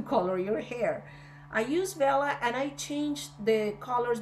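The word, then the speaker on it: Wella: I think it's pronounced Vella. It's W-E-L-L-A.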